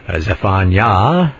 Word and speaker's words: Zephaniah: Zephaniah. Anyway, the accent on the last syllable there.